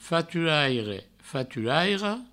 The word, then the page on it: faturaire, faturaira: faturaire, faturairo; Provençal